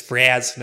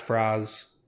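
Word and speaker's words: Fraz-Urb'luu: But yep, it's Fraz, not Fraz. – Yeah, nice nasally A.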